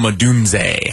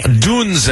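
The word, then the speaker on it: Odunze: Am I saying his name right? I wanted to call him Dunzo, which, of course, is your guy. – He'll be Rome Adunze, but it is Rome Adunze.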